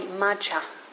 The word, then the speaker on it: majja: So there's a word in Pali, majja. I think that's how you say it, M-A-J-J-A, majja.